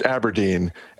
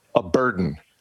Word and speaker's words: abrdn: They're telling us A-B-R-D-N is pronounced Aberdeen and not, as I first looked at it, as a burden.